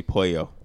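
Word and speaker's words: Pollo Tropical: When I was younger, I used to call it Polo Tropical, until somebody was like, you gotta say Pollo.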